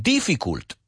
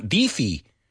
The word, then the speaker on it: difficult: Yo tengo muchos alumnos que dicen difficult. Y digo, pues, ¿te resulta difícil pronunciarlo bien? Di hard, di tough. Pero no digas difficult, it's difficult. Difficult, not difi, difficult.